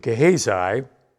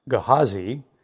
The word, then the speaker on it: Gehazi: Now the king was talking with Gehazi, or more properly pronounced, Gehazi, the servant of the man of God, saying, "Please relate to me all the great things that Elisha has done."